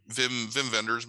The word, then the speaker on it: Wim Wenders: Vim Vendors movie. – Okay. – Wim Wenders, I think is how you say it.